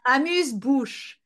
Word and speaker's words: amuse-bouche: It's amuse-bouche.